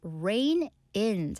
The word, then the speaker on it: rein: And our final expression for this story, rain in. 자, 비 오다 할 때 rain 같지만 스펠링은 R-E-I-N입니다. 자, 이 R-E-I-N, rain은요, 말의 고삐를 얘기하는데요.